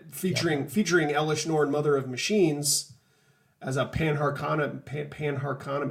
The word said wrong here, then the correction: Panharmonicon: how do you say that card? – Panharmonicon.